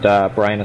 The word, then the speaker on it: Brian: Yeah, if you go to YouTube and just type in my name, Brian Cozier, my last name is Cosier, and Brian is spelt with an I.